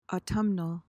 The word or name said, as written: autumnal